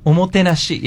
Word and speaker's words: omotenashi: Omotenashi? – Omotenashi, yes.